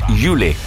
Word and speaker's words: Yeule: Lei si chiama Yule. Yule è un artista di Singapore, si scrive Y-E-U-L-E, nata a Singapore ma ora residente a Londra.